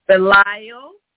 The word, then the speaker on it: Belial: Pronounce it for me, please, sister. – Belial, that's the devil, okay.